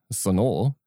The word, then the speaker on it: Sonor: Obviously, I'm a sonar nerd, right? Sonor. Sonor. I never knew is that how you pronounce it until I watched some like factory video from 1994 and the guy's like, sonor. I'm like, what? It's sonar.